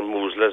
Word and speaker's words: Mousehole: It's a Eh, a small village at the time called Mousehole or Moosle as they pronounce it in Cornwall and the lifeboat launched at about uh,